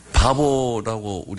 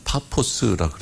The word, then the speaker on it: 파포스: 바보라고 우리가 이렇게 발음하지만 파포스라 그럽니다.